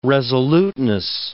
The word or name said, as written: resoluteness